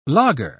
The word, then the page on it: laager: British English